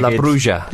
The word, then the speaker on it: La Bruja: La Bruja.